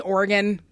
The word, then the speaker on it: Oregon: Oregon is the locals prefer to pronounce it. – Yeah, it's Oregon.